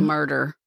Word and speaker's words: Murdaugh: Their name is spelled, it's Scottish, I guess. M-U-R-D-A-U-G-H. But I think it's murder. Murder. Actually.